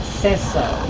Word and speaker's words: Sesso: M-O-L-L-I-E, the initial B, and Sesso is the last name, S-E-S-S-O.